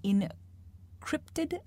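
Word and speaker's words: InCryptid: Her previous works include The October Day and Encrypted, am I pronouncing that correctly? – Series? You are.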